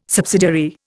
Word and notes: subsidiary: Indian

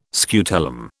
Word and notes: scutellum: British English

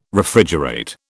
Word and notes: Refrigerate: British English

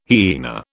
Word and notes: hyena: British English

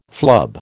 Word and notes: flub: American English

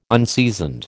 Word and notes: unseasoned: American English

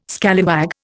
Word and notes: scallywag: Indian

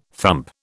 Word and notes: thump: British English

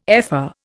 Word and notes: ephor: Indian

